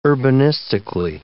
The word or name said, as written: urbanistically